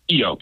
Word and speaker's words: Keyo: Keyo, K's like a key with an O on it.